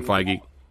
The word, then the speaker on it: Feige: And also, I feel terrible for Kevin Feige. or right. Feige, however you pronounce his name. – Kevin Feige.